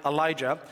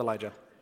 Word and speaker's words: Elijah: It does mean praise the Lord, and the portion of the word for Lord is Yah, so we pronounce the J as a Y, and Yah is seen many times in Old Testament Hebrew names like Elijah, and in English we hit the J, and Elijah, for what it's worth, is a great name for a firstborn son.